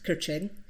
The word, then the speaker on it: Keqiang: Li Keqiang is pronounced Keqiang, obliged with some anodyne comments a la Obama.